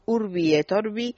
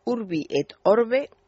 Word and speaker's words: Urbi et Orbi: Y dice ella, en esta ocasión el Papa ha dirigido la bendición Urbi et Orbi, Orbi, a ver, lo digo bien, Urbi et Orbe de una forma especial a Siria ha dirigido la bendición Urbi et Orbe de una manera especial a Siria.